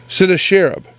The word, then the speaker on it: Sennacherib: And they were spoken by a king, and I'm going to butcher this name. I have it written right here so I can make sure I say it, try to say it properly. Sennacherib.